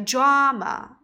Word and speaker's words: drama: 抓啊，然后呢，第二个 m m 字母 a 呢要发 a 的音，所以你看两个啊，两个 a，第一个 a 呢要发长音 r 的音，第二个音呢要发 a 的音，连起来 drama drama，哎 drama，但是你念的时候你别念成 drama，这样念的就很难听了，就直接就是 I like drama，I like drama 啊，第一个 a 发长音，第二个要发短音 a 啊。